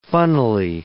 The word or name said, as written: funnily